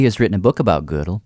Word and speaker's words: Gödel: I hear it often pronounced, well, I don't speak German, but mathematicians often say it like the woman's piece of clothing, you know, from the old days. But something like Gödel.